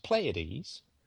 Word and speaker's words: Pleiads: This is Pleiades, pronounced Pleiades.